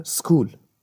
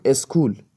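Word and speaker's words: school: تلفظ صحیح انگلیسیش میشه سکول اما تو لحجه فارسی بهش میگیم اسکول همین کلمه تو انگلیسی یک سیلابل داره و توی فارسی دوتا چون ما یه دونه ا آوردیم اولش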